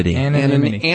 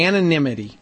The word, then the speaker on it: anonymity: Say it. I never say it right. – Privacy? – Anonymity. Anonymity. Thank you.